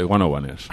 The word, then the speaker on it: 101ers: Pues se escribe 101 Hertz, pero que hace referencia, me parece, a una vivienda ahí de una calle de Londres o no sé qué. Pero vamos, de 101ers.